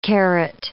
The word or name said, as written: carrot